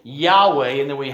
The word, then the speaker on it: Yahweh: And the best way that we think to pronounce that is Yahweh, but it means I am who I am, or I am the one who exists.